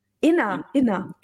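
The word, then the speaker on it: Innr: Ich glaube, Innu wird das ausgesprochen, Inna. Oder? Inna, genau.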